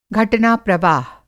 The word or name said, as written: घटना प्रवाह